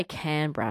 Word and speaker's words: Canberra: I say Canberra, and I always have, and I lived there for a few years. But I've noticed there are still some people who cling on to Canberra. It feels wrong to me. But maybe it's something that, maybe that's how you pronounce it.